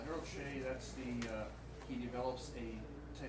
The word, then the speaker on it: Shea: DeCamp actually appears in two rows, but the second row, DeCamp and Pratt, I call it Shea. Harold Shea series. Is that how you pronounce it? – I'm from New York. We have Shea Stadium. – There you go, Shea Stadium.